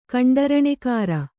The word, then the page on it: ಕಂಡರಣೆಕಾರ: kaṇḍaraṇekāra